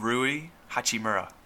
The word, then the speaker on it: Hachimura: Rui Hachimura.